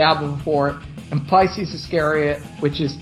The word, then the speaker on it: Iscariot: Do you pronounce it Iscariot? Is that how you say it? Iscariot. Right. Yeah, I count that as an album.